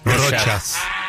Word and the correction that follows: Rochas: Mire, primero vamos a ver la R. – Ay, perdón. – Rochas. – Es que no conocía la marca, perdón. – Bueno, Rochas. Rochas.